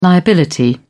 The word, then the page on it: liability: British English